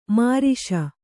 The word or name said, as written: ಮಿರಾಸಿ